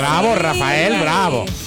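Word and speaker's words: Raphael: Con PH. – Ok, sí. Rafael, ok. – Rafael. – Pero se pronuncia Rafael.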